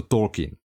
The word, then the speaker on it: Tolkien: Viem, jak sa vyslovuje autor, je to Tolkien.